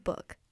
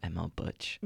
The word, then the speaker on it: Buch: All right, we are on to our last song, which is High Speed Calm Air Tonight by ML Book. I googled how to pronounce her name. – Book. – I hope I have done it well. – ML Book. – ML Book. Spelled B-U-C-H. – Not ML Butch.